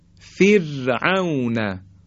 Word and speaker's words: فرعون: فر، فر، فرعون، یا فردوس، فر، اگه بگیم فر این غلط هست، یعنی ما درشت تلفظ کردیم حرف را، یعنی تفخیم کردیم.